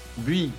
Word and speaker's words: Buis: On dit comment Hugo Buis comme une aiguille.